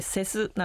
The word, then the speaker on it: CES: はい、はい、そうなんです、コンシューマーエレクトロニクスショー、略称がですね、これ CES と書いてセスなんて言うんですけれども、こういう展示会がですね、毎年 1 月に、開催されています、家電見本市なんて言われるラスベガスであるショーですね。